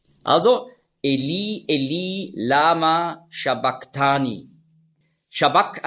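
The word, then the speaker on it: sabachthani: Also nicht Shabach, sondern Shabak.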